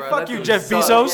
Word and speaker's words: Bezos: You said Bezos. – that's how you say it, right? – No, Bezos.